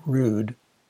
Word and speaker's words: Rood: And then to make matters orders of magnitude worse, our teacher, Mrs. Rood, yeah, that was actually her name, spelled R-O-O-D and pronounced like Rude, had called me to her desk later and admonished me, not fucking Joey, making me feel like some sort of sexual deviant.